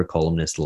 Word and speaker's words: columnist: Yeah, that's how you say it. – Columnist. – Well, it's Salmon too, by the way, and Almond. It's important to pronounce all the letters.